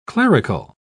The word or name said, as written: clerical